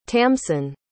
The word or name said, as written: tamsyn